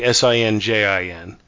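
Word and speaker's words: St John: Here it's actually S I N J I N. But like in Mad Men, there's a character named Sinjin, and it's spelt S T period J O H N.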